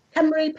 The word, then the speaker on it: Cymru: For those that don't know, Cymru, it's a Welsh word meaning Wales. And it's actually spelt Cymru, but it's pronounced Cymru.